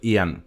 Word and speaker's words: Ian: e devo correggerti la pronuncia. Lui Ian Brady e Myra Henley. – Sì, giusto, Ian, perché è l'unico nome che si legge al contrario in inglese, perché sarebbe esatto. Ian.